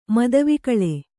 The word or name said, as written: ಮದವಿಕಳ